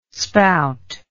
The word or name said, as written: spout